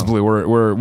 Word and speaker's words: BlazBlue: Like, you just don't even pronounce the Z? – I don't know. Colin and I used to just do it to annoy Clements. Yeah, it's BlazBlue. We're American.